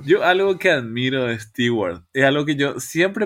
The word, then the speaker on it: Stewart: El fin es que le rompió el ojo a, a Isaiah Stewart, Stewart. Entonces armó un pequeño problema y... – ¡Wow! Muy uh. bien, se sacó el apellido. Muy bien, muy, muy bien pronunciado el apellido.